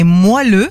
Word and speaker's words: moelleux: Bref, votre oreiller n'est pas moelleux, mais moelleux. – Ah oui, c'est moelleux. – Voilà. – Ouais, c'est ça. Moelleux, ouais.